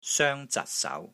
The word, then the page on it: SOENG1 ZAT6 SAU2: Cantonese